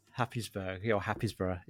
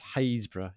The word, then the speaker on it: Happisburgh: H-A-P-P-I-S-B-U-R-G-H. – Happysborough. – Yeah, but it's pronounced Haysborough.